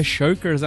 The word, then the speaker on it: Shirkers: Mas, Mas, porque Shirkers é um nome muito complicado, Shirkers.